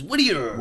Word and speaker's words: Whittier: I was calling Whittier for the first one, and people don't understand any like hard T's. – Yeah. – They just, everything's Whittier. – Whittier. – Whittier. So I had to pronounce it that way.